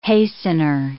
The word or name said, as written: hastener